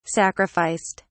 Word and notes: sacrificed: British English

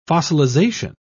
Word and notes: fossilisation: British English

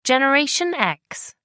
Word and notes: generation x: American English

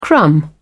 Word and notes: crumb: British English

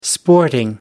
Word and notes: sporting: American English

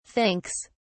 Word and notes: thinks: British English

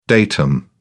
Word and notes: datum: British English

